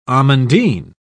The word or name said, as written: amandine